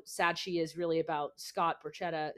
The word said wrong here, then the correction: Borchetta: is that how you say his name bruschetta i feel like it bruschetta like i'm thinking like bruschetta, bruschetta. scott borchetta scott yeah. borchetta um, who was like you know her initial discovery.